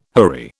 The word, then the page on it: houri: British English